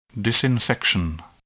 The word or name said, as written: disinfection